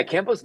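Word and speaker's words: Kambo: Your experience, was it Cambo? Is that how you pronounce it? – Yeah, Cambo's the monkey tree frog, the giant monkey frog.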